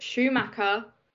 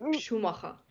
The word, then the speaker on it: Schumacher: So she's like, that's not, you don't pronounce it Schumacher, it's Schumacher.